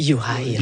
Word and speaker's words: Juraj: Personnellement, moi, j'ai de la difficulté avec Jurav Slavkovski. Oui, Juraj.